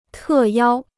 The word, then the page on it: 特邀: tè yāo